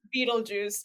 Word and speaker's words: Betelgeuse: I think my favorite star is called Betelgeuse, or some people were pronounced it as Betelgeuse, which I don't think is right, but Betelgeuse sounds fun. – It's Betelgeuse.